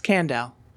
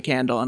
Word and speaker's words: Kandel: It's Kandel. I got out of gym class for 4 years because my gym teacher called me Kandel, and I was like, not doing it.